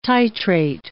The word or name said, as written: titrate